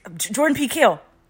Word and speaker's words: Jordan Peele: Jordan Peele.